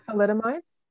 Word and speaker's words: thalidomide: I think it was Talimodid or whatever. I can't pronounce meds names. – Is it the thalidomide? Is that what you're thinking? – Something like that, yeah.